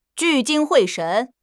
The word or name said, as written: 聚精会神